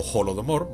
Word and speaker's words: Holodomor: el Holodomor, o Holodomor, bueno, se escribe con H así que lo digo Holo,